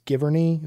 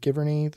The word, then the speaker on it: Giverny: I think it's Giverny. Is it Giverny that he did this at? – Giverny!